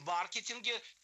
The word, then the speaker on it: маркетинг: В маркетинге, кстати, ударение все-таки на «а», классическое. – Пока маркетинг. – Да, правильно. – Из уважения хотя бы к родине придуманного слова.